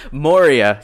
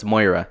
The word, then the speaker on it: Moira: When I first heard it, I was saying Moria, you know, like Lord of the Rings, but it's Moira.